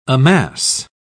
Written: amass

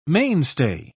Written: mainstay

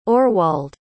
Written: orwald

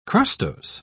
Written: crustose

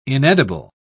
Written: inedible

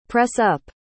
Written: press-up

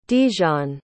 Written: Dijon

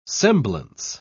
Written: semblance

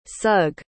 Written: sugg

